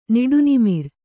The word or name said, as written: ನಿಡುನಿಮಿರ್